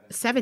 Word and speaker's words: Sevigny: Chloe Sevigny. Sevigny? – Yeah. – Sevigny? Seven? – Sevigny. – Like Sevigny. Okay.